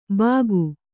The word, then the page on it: ಬಾಕು: Kannada